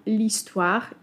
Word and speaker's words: histoire: histoire czy hotel, gdzie powiemy odpowiednio l'histoire i l'hôtel. Dzieje się tak, ponieważ w wyrazach histoire i hotel mamy tak zwane h muet, czyli h nieme, a w przypadku n jest to h aspiré, czyli h przydechowe.